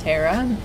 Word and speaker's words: Tara: Do you say your name Tara or Tara? – Well, my parents called me Tara. Tara Dawn.